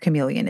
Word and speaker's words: Camylleon: So chameleon, and this is spelled, it's not like the regular chameleon, but it's pronounced like chameleon. It's spelled C-A-M-Y-L-L-E-O-N.